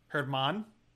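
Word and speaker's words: Germán: Jermaine Marquez, I mean. – Herman? Come on, Herman, whatever. Are you for real right now?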